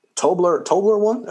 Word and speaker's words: Toblerone: Toble run. Okay. – Did I say it? Toble run. – Yeah, that's how it's broken up. Toble, and then spaced Rone. Toblerone.